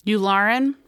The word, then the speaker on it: Yularen: And they were going to, you know, Admiral Yularen was, or your, how do you pronounce it? – Yularen. Yeah.